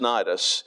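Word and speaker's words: Cnidus: Then we had sailed, and when we had sailed slowly many days and were scarce come over against, that's pronounced Nidus, the wind not suffering us, we sailed under Crete against Salmone.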